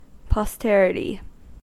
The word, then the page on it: posterity: American English